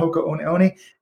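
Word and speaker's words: Hoka One One: And now it was kind of like, how do you say it? Hoka 1-1, Hoka Oni Oni.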